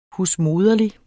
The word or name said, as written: husmoderlig